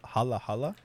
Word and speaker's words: halo: Hala? – Halo. It's halo, spelled halo? To look yeah, like halo, halo, but it's pronounced hala, halo.